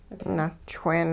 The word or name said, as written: na'chween